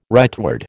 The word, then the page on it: rightward: American English